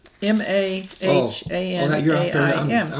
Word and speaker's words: Mahanaim: M A H A N A I M. – I was looking at the verse 2. – Yeah, in verse 2, it's Mahanaim. Mahanaim.